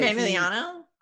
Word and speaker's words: Manganiello: But Manganiello.